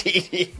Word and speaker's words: Didi: It's Diddy Gregorius. Yep. It's spelled like D-I-D-I, so.